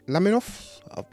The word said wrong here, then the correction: Lanthimos: I want to get his name right. Lanthimos. I definitely messed it up.